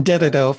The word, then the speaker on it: Dededo: I lived in Dededo for two years when I was young.